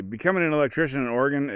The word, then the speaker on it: Oregon: you can say Oregon, like a heart and a lung, or you can say Oregon, if you want to get fancy. But I recommend just saying Oregon.